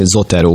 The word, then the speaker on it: Zotero: Úgy hívják ezt, hogy Zotero, úgy kell kiejteni, Zotero,